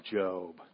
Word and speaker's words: Job: And she goes, honey, that's Job. And I said, there should be an E on the end of that then. But she goes, no, it's pronounced Job. And that's, I started to read about Job.